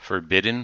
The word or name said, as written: forbidden